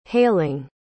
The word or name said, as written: hailing